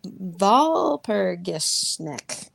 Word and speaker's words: Walpurgisnacht: Aren't the W's pronounced with a V? – You're just wanting me to make it the same, make me say it again, aren't you? – little bit. – Walpurgisnacht.